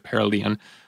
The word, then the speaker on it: perihelion: A friend actually sent me how to pronounce the word, and I'm saying it wrong, but I'm going to stick with perihelion. Perihelion? Perihelion is, the correct pronunciation.